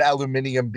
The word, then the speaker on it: aluminium: But I love I pronounce it aluminium because there's an I between the U and next to the U.